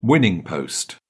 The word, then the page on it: winning post: British English